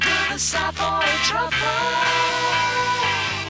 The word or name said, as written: Savoy Truffle